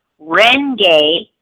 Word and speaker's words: Renge: But it's Nam, which is N-A-M as in Mary, Myoho, M-Y-O-H-O, Renge, R-E-N-G-E, Kyo, K-Y-O.